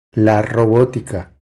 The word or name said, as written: La robotica